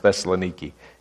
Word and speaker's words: Thessaloniki: For the Bereans were more noble than those in Thessalonica, in the Greeks pronounced Thessaloniki, in that they received the word with all readiness of mind, searching the scriptures daily to see whether these things were so.